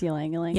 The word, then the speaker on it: Ylang Ylang: Number three is the balance oil, and it's got uh, Lang Lang oil. I can never say that. – Ylang yep. Ylang. – It's Ylang Ylang. – Ylang Ylang. I can never say that right.